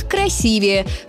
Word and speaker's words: красивее: Единственно верный вариант – красивее, с ударением на второй слог.